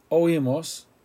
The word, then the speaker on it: oímos: We heard, oímos, O-I-M-O-S, accent.